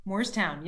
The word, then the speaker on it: Moorestown: And now you are head of school at a friend's institution in Moorestown? – Moorestown, yes, well pronounced.